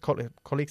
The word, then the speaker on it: colleague: My secretary's husband's colleague's computer. – Good. But colleague se pronuncia colleague.